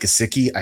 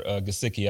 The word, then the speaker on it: Gesicki: And I apologize for Gesicki. I said his name wrong earlier than I've been saying Gesicki this entire time. So I apologize to that guy. Yeah. – It goes every which way. Gesicki.